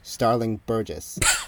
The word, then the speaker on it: Starling Burgess: by the amazingly named typographer Starling Burgess. – That wasn't so. Is that it? Yeah, no. – No, you said it right.